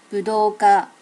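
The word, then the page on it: budoka: native Japanese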